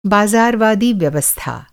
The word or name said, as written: बाज़ारवादी